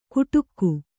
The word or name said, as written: ಕುಟುಕ್ಕು